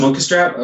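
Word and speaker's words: Munkustrap: And we just talked about this cat, Monkestrap? – Yeah, Monkestrap. – Oh, it's Monkestrap? Okay, so I did say that right.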